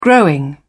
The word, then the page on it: growing: British English